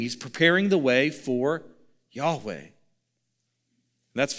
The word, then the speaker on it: Yahweh: You might have heard that pronounced as Jehovah. It's a mispronunciation, probably best pronounced something like Yahweh.